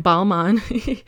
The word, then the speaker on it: Balmain: So I looked it up, and apparently it's Balmain Jeans. I mean, Balmain. But the French don't really pronounce the N at the end. And the reason for that is because the French don't really pronounce the N's when it's attached to a vowel. So Balmain is B-A-L-M-A-I-N. A-I-N. So I guess that's why the N kind of disappears.